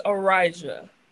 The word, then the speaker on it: Arysia: It's Arysia.